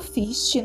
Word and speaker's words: FHIST: Lembrando que FIST se escreve F-H-I-S-T mudo.